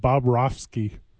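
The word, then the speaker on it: Bobrovsky: There's a guy named Bob Rofsky. That's a last name only. It's not Bob, then Rofsky.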